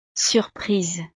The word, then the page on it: surprise: frances